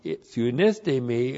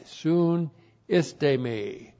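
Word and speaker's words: Sunistemi (συνίστημι): Sunistemi is with the objective negative u, which slams the door. We absolutely are not commending ourselves. Sunistemi. S-U-N-I-S-T, long E-M-I, soon istemi.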